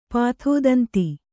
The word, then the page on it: ಪಾಥೋದಂತಿ: pātho danti